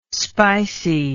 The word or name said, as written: spicey